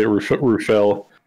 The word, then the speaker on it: Rufail: it's you can say it a couple ways. So no, we really? Say Rufail. It's actually a derivative of Rafael, so it's Rafael.